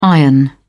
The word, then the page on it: iron: British English